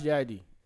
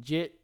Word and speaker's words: JID: He said his grandmother calls him JIT, like J-I-T. – Yeah. – But she pronounced it J-I-D, like JID. – Yeah, JID. JID. – Instead of saying JIT, she said JID. So, yeah. That's why it's pronounced JID and not JID.